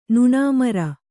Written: ನುಣಾಮರ

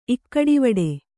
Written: ಇಕ್ಕಡಿವಡೆ

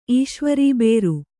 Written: ಈಶ್ವರಬೇರು

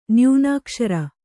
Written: ನ್ಯೂನಾಕ್ಷರ

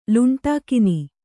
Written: ಲುಂಟಾಕಿನಿ

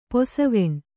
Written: ಪಸೆವೆಣ್

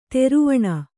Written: ದ್ರುವಣಿ

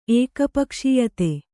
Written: ಏಕಪಕ್ಷೀಯತೆ

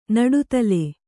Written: ನಡುತಲೆ

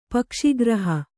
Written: ಪಕ್ಷಿಗ್ರಹ

